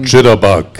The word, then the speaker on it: Chitterbug: Und bei mir ist jetzt das nächste Interview schon parat, und zwar die Chitterberg Bites. wie spricht man das genau aus, wenn ich nochmal... – Chitterbug. – Chitterbug Bites. – Okay, Chitterbug Bites.